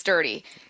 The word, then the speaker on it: Durde: It's pronounced Durde, y'all. Let me remind you, we are at Seahawks headquarters, which means we have been told by Seahawks staff that it is dirty.